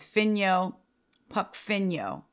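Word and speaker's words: Phinyo Pukphino: His name is Finyo Pukfinyo. I promise you that's how you say it, I think. Finyo, P-H-I-N-Y-O, Pukfinyo, last name, P-U-K-P-H-I-N-O, who's 49.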